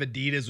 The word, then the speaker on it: Adidas: I think it's Adidas. Is that right? – I think it is too. – Is that how you pronounce it? – Yeah.